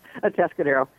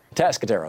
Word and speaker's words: Atascadero: Atascadero. – Atascadero.